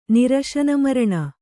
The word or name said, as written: ನಿರಶನಮರಣ